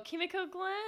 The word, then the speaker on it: Kimiko: A- Uh, like and, the, and Dawn and Dawn is pl- uh, on Broadway is played by Kikimo Glenn, which is in uh, she was in that ghosting movie that we watched. – Oh, Kimiko Glenn.